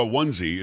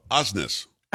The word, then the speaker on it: Osnes: Well, don't look up Laura Onesie. It's Laura Osnes.